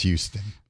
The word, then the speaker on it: Houston: It's pronounced Houston. – This is something we can tell the hayseeds out there. It's Houston here. I don't know why. It's because we say it wrong.